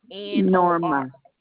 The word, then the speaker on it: Norma: Norma Salinas. – Last name? Spell it for me, please. – Salinas. Salinas. – S-A-L. – I-N-A-S. – N-A-S, and that first name is Norma. – N-O-R-M-A? – Yes, ma'am.